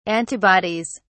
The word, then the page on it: antibodies: British English